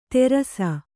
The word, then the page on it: ತೇರಸ: kannada